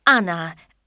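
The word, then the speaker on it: Ana: Anna. That's A-N-A.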